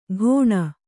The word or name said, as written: ಘೋಣ